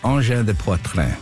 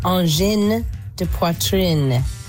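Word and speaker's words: Angine de Poitrine: It's pronounced engin de poitrine. You pronounce the N because there's an E's at the end of those words.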